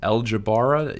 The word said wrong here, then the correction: Abdeljabaria: yeah? – Abdeljabaria. – I should know your last name. Abdeljabaria. Okay, Abdeljabaria. Right. So if you put that on the map, Abdeljabaria, then you would be like, what the hell is that?